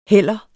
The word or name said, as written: heller